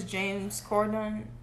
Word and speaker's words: James Corden: Well, she got robbed that award show because James Corden, what, that's how you pronounce his name, right? – Yes.